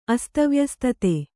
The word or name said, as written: ಅಸ್ತವ್ಯಸ್ತತೆ